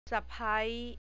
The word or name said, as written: sapai